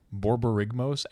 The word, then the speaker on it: Borborygmos: By the way, it's pronounced Borborygmos, and it's not that hard.